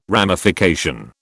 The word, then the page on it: ramification: British English